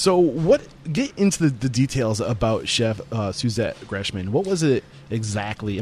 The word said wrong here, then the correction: Gresham: Am I saying her name correctly? – Gresham. – Gresham. Yeah. Thank you.